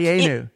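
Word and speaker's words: Dayenu: And there's that sort of dianu. I'm probably not even pronouncing it right. It's a word I know from reading. But that whole like. – Dianu. – Dianu, thank you.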